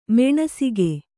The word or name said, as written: ಮಾನಸಿಕ